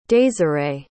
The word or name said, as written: désirée